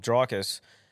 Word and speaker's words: Dricus: But that fight with Dricus, did I say that right for the first time he lost? – You did. – Isn't it? I thought it was Dricus. – No, Dricus.